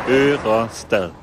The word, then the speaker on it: Ørestad: Ørestad.